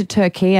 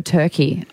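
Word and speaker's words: Türkiye: Why have they changed their name to Turkey instead of Turkey? – It's the official pronunciation. – Okay. – The English have not been saying it right.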